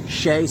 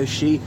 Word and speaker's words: O'Shea: Professor Roy Foster hates when people pronounce Shea, says it's actually O'Shea, and don't call her Kitty.